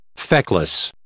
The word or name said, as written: feckless